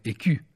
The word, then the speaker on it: écu: i francesi pronunciavano come écu, e quindi col significato anche di scudo.